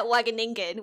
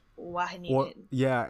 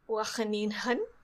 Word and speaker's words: Wageningen: Well, just the Dutch pronunciation of the G is a H sound. – Wageningen. – Or it's more like the French R sound. – Wageningen.